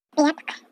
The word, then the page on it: piyaapihkw: Chisasibi